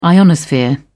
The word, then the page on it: ionosphere: British English